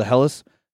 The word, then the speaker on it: Helles: And their, how do you pronounce that again? The Helles? The Helles? – Helles? – Yes, the Helles.